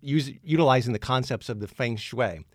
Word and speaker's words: Feng Shui: Well, you know, I can't pronounce it. I can spell it, but it's Feng Shui, right? – Yes.